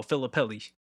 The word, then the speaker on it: Filippelli: It's not how you say your last name, but you was close. – How do you say it? – How would you say? Say it again. – Phillippe. – That's not it. Yeah, it is. Dude, what is it? – No, it's not. Huh? – What is it? – Look up her name right now. Just look at how it's spelled.